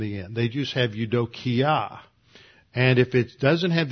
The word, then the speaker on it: eudokia: eudokia. That's spelled e u d o K-I-A, Eudokia.